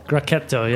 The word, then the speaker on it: Grechetto: Did I pronounce that right? – Grechetto, yeah.